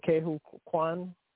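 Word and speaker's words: Ke Huy Quan: Kehu Kwan.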